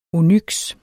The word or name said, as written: onyks